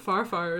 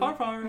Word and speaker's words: farfar: Farfar is how you say grandfather in Norwegian, though. – Farfar. Mm-hmm. – Farfar.